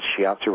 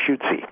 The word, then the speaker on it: Shiatsu: We took her upstairs, put her in bed, and I said, and I didn't, by the way, pronounce Shiatsu right then. I said, like, I just learned this new thing. It's called, I think it's Shutsi.